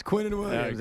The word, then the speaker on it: Quinnen Williams: Quinnen Williams. – There you go. – Did I say it right? – Yes, Quinnen Williams.